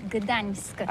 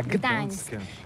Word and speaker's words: Gdańsk: Ça s'écrit G-D-A-N-S-K. On dit Gdansk ou Gdansk, on prononce le G ou pas? – Oui, c'est pas très facile pour des étrangers, mais remarque bien que la langue polonaise, c'est la langue où on prononce chaque lettre qu'on écrit. C'est pourquoi on dit Gdańsk.